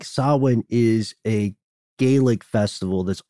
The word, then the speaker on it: Samhain: And if you want to sound smart in front of your friends, or at the very least not sound dumb, it's not pronounced Samhain. It's pronounced Samhain. – Samhain, that's correct. When you see it printed, though, it looks like Samhain.